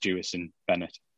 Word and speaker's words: Jewison Bennette: Um, by the way, I think it is actually pronounced Jewison Bennette, um, but I will double check that and confirm at a later date.